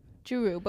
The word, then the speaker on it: Churu: Churro, but we just call it churro in our household. – Is it churro? – It's spelled C-H-U-R-U. Churro.